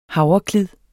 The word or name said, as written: havreklid